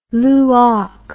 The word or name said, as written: luach